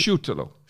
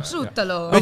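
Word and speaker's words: Šutalo: Voordat je verder gaat, hoe spreek je nou zijn naam uit? Want jij hebt Kroatisch. – Soetalo. – Soetalo.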